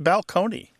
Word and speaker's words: balcony: My favorite change that I love to talk about when Martha and I go and do live events in the rows is the word balcony, you know, like this place on a stage that you can look down upon the seats, used to be pronounced balcony.